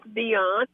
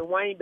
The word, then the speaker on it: Deuane: It's Miss Dion. Everybody always Dion. Say Dwayne because of the way it's spelled, like Dwayne, D-E-U-A-N-E. But it's actually Dion.